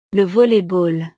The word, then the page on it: le volleyball: frances